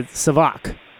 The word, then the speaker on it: SAVAK: And then you said, I think I was mispronouncing it. It was a Savak, right? So I was putting the enunciation on the first syllable instead.